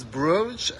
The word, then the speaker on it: Bruges: English is Bruges, and then Flemish is Brugge.